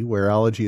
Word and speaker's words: Wearology: That's spelled Wareology. Wearology is how it's pronounced.